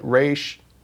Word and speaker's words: Ra's al Ghul: And you did Ra's al Ghul or Ra's al Ghul. There's two schools of thought about how you say his name. – There's such a debate. But you say Ra's. – I say Ra's.